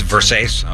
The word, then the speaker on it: Versace: It's Versace.